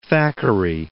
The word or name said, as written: Thackeray